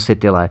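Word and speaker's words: Sipilä: zatímco ve Finsku premiér Juha Sitile je rovněž podnikatel. – No tam je právě to A, který se vyslovuje trošku jinak, ale to nevadí.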